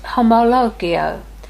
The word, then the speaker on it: homologeo: The first word, confess, is the Greek word homologeo, which means to say the same thing as someone else. I'll spell it for you H O M O L O G E O. Homologeo.